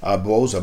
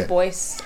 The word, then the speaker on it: Du Bois: Bose or Bois, however you want to say it. – Du Bois, I don't know. – I know the S is silent.